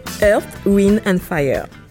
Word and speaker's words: Earth, Wind and Fire: Donc c'était Earth, Wind and Fire.